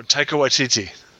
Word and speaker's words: Taika Waititi: Produced by Jon Favreau and Takiti Wakaka. Fuck, I know I said his name wrong. The guy who directed Thor 3. – Wow, Taika Waititi.